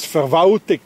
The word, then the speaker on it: Verwaltung: In Bern, that's being pronounced as Verwaltung. Anybody hears an L? No, it disappeared.